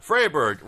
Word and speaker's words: Freiburg: And following that, we're going to see Freiburg versus Braga.